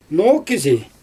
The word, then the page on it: noo kizi: Xyzyl